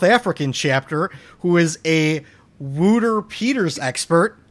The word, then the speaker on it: Wouter Peeters: That is actually a sound clip from Burns Churches that he sent me on how to pronounce Wouter Peters' name. – Wouter Peters. – Wouter Peters. – Vote. Yeah, I like Wooter Peters better. – I know we have the typical Chicago / American pronunciation of that name. We fucking Wooter mangle penis. It. Yeah, we mangle the shit out of that thing.